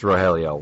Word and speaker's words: Rogelio: I should know that's Rogelio. Why would it be a hard G?